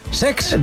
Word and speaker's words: Cesc: Lo he dicho mal, es Sex.